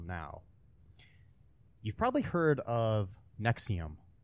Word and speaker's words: NXIVM: The Nexium is NXIVM is how it's spelled.